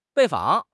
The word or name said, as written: 拜访